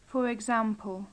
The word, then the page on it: for example: English